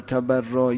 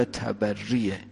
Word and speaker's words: تبری: تو عربی صحیحش تولی و تبریه، ما همون به زبان و همون تلفظ عربیش تلفظ میکنیم، درست فارسیشو شما خودتون تصحیح کنی اگه این صحیح نیست.